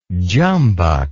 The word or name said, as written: jumbuck